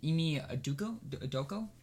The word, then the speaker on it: Ime Udoka: Ime Udoka.